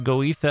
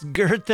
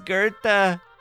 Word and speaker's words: Goethe: And hey, when I hear Adele singing her song and she gets it all wrong, poor Adele, she doesn't know how to pronounce that German poet's name. She says Goethe on me. Huh. Oh, I don't laugh and say, it's Goethe, Adele, it's Goethe.